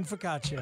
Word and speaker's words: focaccia: It's going to have French onion soup, cinnamon focaccia, and ribeye. – You don't pronounce the I in focaccia.